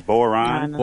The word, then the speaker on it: Boiron: So this time, homeopathy has a corporate face, which I think is interesting because you have the multinational pharmaceutical company how do you pronounce that? Is it Boron? Boiron? How would you pronounce that? – Yeah, Boiron. – Boiron. – Boiron. – I would pronounce it Boiron. – Boiron. – Like Chef it's a French company.